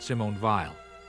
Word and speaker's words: Simone Weil: He prefers the pronunciation Simone Weil in the 1950s.